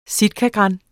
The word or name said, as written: sitkagran